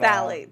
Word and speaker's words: phthalates: These things called phthalates. – I was going to ask you how to pronounce this because it's spelled P-T-H-A-L-A-T-E-S. – Yeah, so the P-H, I guess, is silent, essentially. – So but, phthalates.